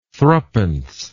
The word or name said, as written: thruppence